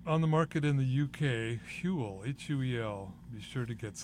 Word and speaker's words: Huel: You catch Huel? It's from England. Rhymes with gruel, rhymes with fuel.